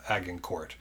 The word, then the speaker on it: Agincourt: So, the example I want to use of these five decisive factors is the Battle of Agincourt.